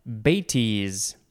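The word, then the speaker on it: bêtise: Now we have betis. It is spelled B-E-T-I-S-E. And there is a little carrot accent on the first E.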